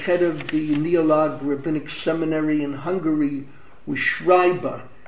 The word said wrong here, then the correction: Scheiber: It's Schreiber. The name is spelled S-C-H-E-I-B-E-R.